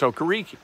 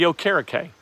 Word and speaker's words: Okereke: And they, when Bobby Okereke was drafted, he told everybody his last name was pronounced Okereke.